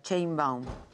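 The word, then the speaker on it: Sheinbaum: Dice, mi sobrina escribe como se pronuncia Chainbaum. – Chainbaum. – Así dicen, Chainbaum.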